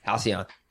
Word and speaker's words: Halcyon: You know, like when I was in Philly, I I went to Halogen Floats. Halcyon. I always say it wrong.